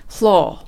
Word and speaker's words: floor: そのためフロアの発音がですね、 floor って R をあまり発音せずにされています。